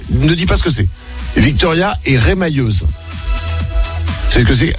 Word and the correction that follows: remailleuse: Remailleuse. – Ça se prononce pas comme ça? Remailleuse? – Remailleuse, oui. – Ah, remailleuse, pardon.